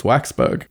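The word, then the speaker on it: Waksberg: Today I realized that I pronounced Raphael Bob Wakesburg's surname wrong, but am I going to change it or bring it up on the show? No. It's Waxburg.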